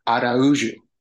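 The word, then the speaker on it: Araújo: It's Araujo. – Oh, okay. All right. – Yeah, all right. – It's Portuguese. And so they have that J makes like a weird juju sound.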